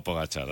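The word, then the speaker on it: Pogačar: Pero es así, se le conoce como Pogacar, así es, la pronunciación en español, ¿no?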